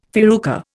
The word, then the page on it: farruca: Indian